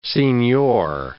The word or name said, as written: signor